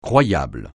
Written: croyable

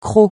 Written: croc

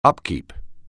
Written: upkeep

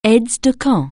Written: aide-de-camp

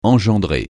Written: engendrer

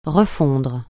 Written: refondre

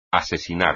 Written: asesinar

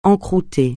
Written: encroûter

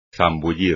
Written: zambullir

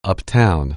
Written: uptown

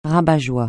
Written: rabat-joie

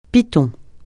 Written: piton

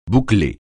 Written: boucler

